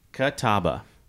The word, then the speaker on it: Catawba: Catawba. Yeah. – You cheated. You looked that up. – No, I mean, that's what it looks like to me. Catawba.